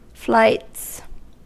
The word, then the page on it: flights: American English